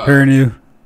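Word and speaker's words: Perrineau: Because they had the Constantine series that came on with Harold, was it Piranan or something? What was this? – I think it's pronounced Piranew.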